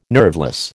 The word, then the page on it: nerveless: American English